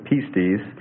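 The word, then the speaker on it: pistis (πίστις): pistis, And it means persuasion, conviction of truthfulness of God, assurance, belief, and fidelity.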